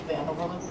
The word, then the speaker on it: Van Overloop: Yep, Lieutenant Nicole Van Overloop. – And you'll have to spell that. – V-A-N-O-V-E-R-L-O-O-P.